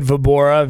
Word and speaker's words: Vobora: David Vobora, V like Victor, O, B like boy, O-R-A.